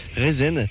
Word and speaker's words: Rezn: Rezn, R-E-Z-N, ça se prononce comme ça, et c'est beaucoup de choses à la fois.